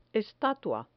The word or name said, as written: estatua